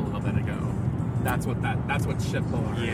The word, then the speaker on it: Köttbullar: And they're like, "You do not pronounce it like the way you pronounce it in Germany." And I was like, "So how do you pronounce it?" "Yeah, like Shitbullar." And I'm like, "Well, if you're in an English-speaking country, that does not sound very delicious."